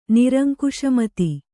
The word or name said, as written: ನಿರಂಕುಶಮತಿ